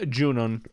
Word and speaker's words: Junon: So I would argue that I think Junon, is that how you say her name? – Yeah, Junon. June would be the English translation of that.